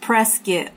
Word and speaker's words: Prescott: And they literally have shirts that say it's pronounced Prescott, like biscuit.